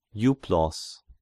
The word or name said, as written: Euplos